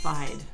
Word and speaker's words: Feid: F-E-I-D. Would you call that fide? – Fide.